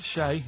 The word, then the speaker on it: Che: Che is like shake, and his surname is like guitar.